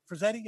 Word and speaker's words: Frazetta: So a lot of it was based on the Frazetta run of, is it Frazetta? Am I saying that right? – Frazetta.